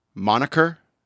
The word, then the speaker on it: moniker: Okay. Monkier? – The monkier. Monkier? – Moniker? – Moniker? Is that it? – Spell it for me. – Monkier. – Moniker. – Okay. It looks like monkier.